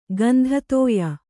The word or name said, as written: ಗಂಧತೋಯ